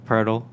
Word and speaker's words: Poeltl: Jakob Poeltl. Am I saying that right? – Nailed it. It's like turtle but with a P.